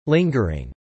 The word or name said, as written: lingering